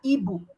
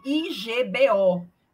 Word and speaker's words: Igbo: A história é o seguinte, na cultura africana da Nigéria, é, a cultura era Ibo, se escreve I G B O e todo mundo manda a gente pronunciar Igbo, mas é Igbo, né? A pronúncia, a, a escrita é Igbo.